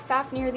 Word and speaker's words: Fafnir: Next, we have Fafnir, the Elemental Dragon Guardian of Fire, and this is spelled F-A-F-N-I-R, but is pronounced F-A-F-N-E-E-R, like near, not like Nair, because that's kind of how it's spelled, so we're gonna call him Fafnir instead of the way it's like spelled.